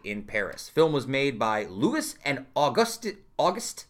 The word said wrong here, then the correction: Auguste: It's August with an E at the end. Am I supposed to pronounce that differently? – Auguste Lumiere, the two French brothers who developed a camera projector called the cinematograph.